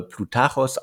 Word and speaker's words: Ploutarchos: Äh, ich glaube, der Name, Nachname spricht sich äh, Plutarchos aus, also der ehemalige Kiez-Gründer.